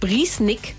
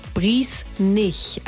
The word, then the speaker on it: Briesnick: Es heißt Briesnick und nicht Briesnich. Also Briesnick nicht. Briesnick heißt Briesnick wie nicken.